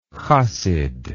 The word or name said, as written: chasidism